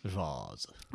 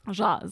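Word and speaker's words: Jâze: Alors prononcez bien à la française, hein, l'orthographe le suggère lourdement. Jazz, ça s'écrit J-A accent circonflexe Z-E. – Jazz. – Jazz, voilà. Alors à trois, accordéon diatonique, saxophone, baryton et tambour, on dirait déjà une fanfare.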